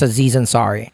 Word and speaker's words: Aziz Ansari: It's Aziz Ansari.